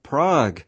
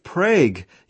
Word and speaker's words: Prague: I have noticed that you still pronounce Prague in the Germanic form of Prague, instead of Prague, you know, like Hague and Sprague.